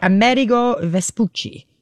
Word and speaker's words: Amerigo Vespucci: Is the Amerigo Vespucci.